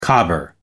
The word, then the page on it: cobber: American English